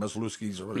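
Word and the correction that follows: Misiorowski: Yeah. – Threw 10 pitches over 103 miles an hour. – Mizorowski. – Yeah, you say his name? Mizor.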